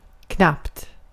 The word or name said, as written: knappt